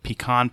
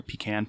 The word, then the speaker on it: pecan: They have, if you like pecan pie or pecan pie, depending on the pronunciation you prefer, they have a version of that made with peanuts called peanut pie, and it's really fantastic.